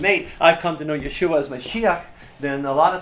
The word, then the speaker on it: Yeshua: In the Jewish home, saying that I have met Yeshua, because in Hebrew it's Yeshua, but Orthodox Jews call him Yeshu. And it's actually pronouncing his name as an insult, and it says that name Yeshu means may he be forgotten forever.